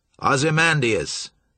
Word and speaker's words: Ozymandias: Ozymandias, said Fram.